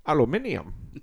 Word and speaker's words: aluminium: Crush you like an aluminum can. Aluminium. That's how the Brits say it. Is that how you say it in jolly old England? – Listening to my dyslexia.